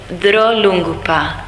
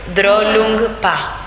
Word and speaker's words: Drolungpa: Geshe Drolungpa. D-R-O-L-U-N-G-P-A. – P-P-A. – Drolungpa. – Pronounced Drolungpa.